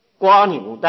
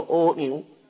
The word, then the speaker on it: 蜗牛: 那么政治证分的比喻做可，像瓜牛的或者念作蜗牛，它的可；自证分的比喻做陀，啊，这个属于体，它属于性，性是圆灭，它唯独是属于内证，这个属于圆灭。